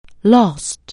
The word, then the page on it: lose: anglais